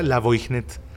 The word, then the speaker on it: Lavoignet: Ah, se pronuncia La Voignet. Así como se escucha.